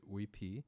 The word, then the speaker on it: Uypi: The Spanish spelled this, of course, Native people didn't have a written language, so the Spanish spelled it U-Y-P-I. And I always say when people ask, how do you pronounce these things? You think of it as you're reading it from a Spanish accent, right? Because these are written by Spanish speakers in their tongue. And so I pronounce it Uipi.